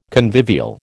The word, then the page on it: convivial: American English